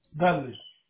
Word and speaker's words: Dudley: Dudley. – How do you spell that? – D-U-D-L-E-Y.